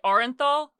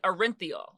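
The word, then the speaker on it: Orenthal: Yes, this is how he pronounces it. I know a lot of people say Orinthol, but he pronounces it Orinthiol, or he pronounced it.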